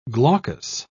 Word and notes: glaucous: British English

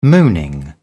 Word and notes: mooning: British English

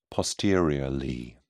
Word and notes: posteriorly: British English

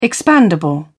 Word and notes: expandable: British English

